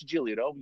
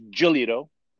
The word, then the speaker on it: Giolito: You know, it's like when people say, oh, you know, it's Giolito. No, it's Giolito, you know. But you know, the proper way to say it is Giolito.